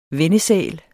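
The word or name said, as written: VENNESÆLT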